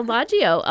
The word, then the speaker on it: Selvaggio: Is it Selvaggio? – Selvaggio. – Oh, I did it. – Kind of rhymes with DiMaggio. – DiMaggio, so. Selvaggio.